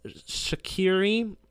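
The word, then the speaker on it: Skhiri: 24-year-old Elias Shaqiri, S-K-H-I-R-I. I know it's not pronounced like Jerdan Shaqiri, but cut me some slack.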